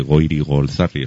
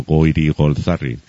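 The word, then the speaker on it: Goirigolzarri: Lo que te comentaba al principio, si quieres ser el alma de las tertulias, pues apréndete bien este nombre, Goiri Golzari. Es complicado de pronunciar, yo lo he tenido que pronunciar varias veces, pero una vez que lo conoces, te sale solo Goiri Golzari,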